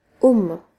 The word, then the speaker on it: AUM: Euh, AUM, en fait, c'est OM, concrètement. Je sais que le son om euh, aujourd'hui on l'écrit OM, mais traditionnellement ça s'écrit AUM et silence.